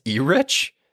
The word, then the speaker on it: Erich: It is spelt, and I thought it was E-Rich. It is E-R-I-C-H. – Wow, that's bizarre. – Right, but it's Eric.